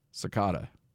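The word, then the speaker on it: Secada: Hey, coming in at number 23, If You Go from John Cicada. Did I say that right? – You said it right, okay, good.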